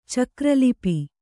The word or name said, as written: ಚಕ್ರಲಿಪಿ